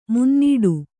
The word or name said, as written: ಮುನ್ನಡೆ